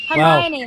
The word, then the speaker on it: Hermione: You said it right. Yeah. Well done. – I did? – Hermione, wow. Like in Harry Potter.